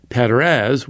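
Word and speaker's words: Peres: And Perez, which is how you would pronounce it, means divide.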